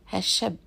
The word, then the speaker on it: هالشاب: In fact, the word شاب is not pronounced with a long A in the Levantine Arabic. It's pronounced with a short A, هالشاب شو معقد.